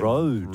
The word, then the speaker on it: Rhode: If you say Rode. – Rode Skincare. – Rode Skincare. – Rode Skincare on my face. – It's spelt like R-H-O-D-E. Rode. It's not spelt like R-O-A-D.